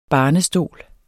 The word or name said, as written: barnestol